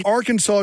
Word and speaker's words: Arkansas: Arkansas is the way you have to pronounce it.